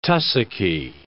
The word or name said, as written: tussocky